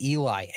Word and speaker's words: Elijah: I found out today his name is spelled like it's Elijah, but his name is Eli. It's weird how it's spelled. His name is that, and it's that Eli Dickin.